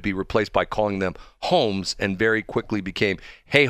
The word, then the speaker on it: Holmes: And they're not saying H-O-M-E-S. They're essentially saying H-O-L-M-E-S, but the L is silent.